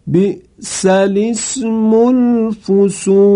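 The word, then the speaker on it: بئس الاسم الفسوق: جوابش این هست که برای امکان تلفظ لام ساکن رو مکسور می کنن و عبارت رو چنین می خوانن. بی سل اسم الفسوق، یک بار دیگه می خوانم، بی سل اسم الفسوق بعد الایمان.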